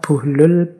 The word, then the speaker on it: Buhlul: Ini ada yang membacanya Bahlul, ada yang membacanya Buhlul. Saya milih membaca Buhlul saja ya.